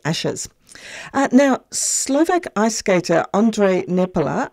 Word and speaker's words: Ondrej Nepela: Yes, so, and a very good pronunciation. So it is Ondrej Nepela, well done.